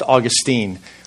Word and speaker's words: Augustine: I want to begin this morning with a brief story about Augustine, or maybe you've heard it pronounced Augustine, one of the greatest theologians in church history.